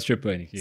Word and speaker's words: Stepanik: Então deixa eu apresentar aqui, Rafael, esqueci como é que eu tenho que pronunciar teu nome, Stepanik. – Rafael Stepanik, isso.